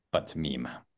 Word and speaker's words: meme: So I hear a lot of people saying memes, but maybe in different languages, they say they change the pronunciation, but this was like meme. Okay. Meme. So the first E is E. The last E is silent. Not meme, not meme. But meme.